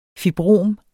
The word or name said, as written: fibrom